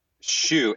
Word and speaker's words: Shew: Shew, S-H-E-W, like the tennis shoe, yeah. – Yeah, but it's spelled S-H-E-W, so maybe people mess it up.